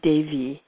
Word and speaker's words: Devi: The name Devi, D-E-V-I, the E is pronounced like A, is the feminine form that is a feminine form that means shining one, and there's a masculine form, Deva, as well.